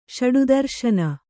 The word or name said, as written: ಷಡುದರುಶನ